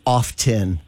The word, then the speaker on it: often: No, what word is always mispronounced is often. The T is silent.